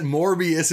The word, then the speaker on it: Morbius: is just like that Morbius is funny to say. And that like Morb yes. rhymes with orb.